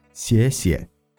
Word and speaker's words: 谢谢: سيسي